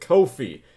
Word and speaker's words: Ko-fi: Ko-fi. – Well, it might be coffee, but it's spelled K-O-F-I, and that's weird, so I say it Ko-fi.